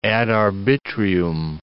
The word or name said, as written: ad arbitrium